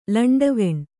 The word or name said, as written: ಲಂಟವಾಣಿ